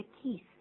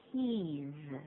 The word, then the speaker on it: keys: 对，加 s 变复数，可是请注意发音，念作 z 的音， keys 并不是 keys，是 keys。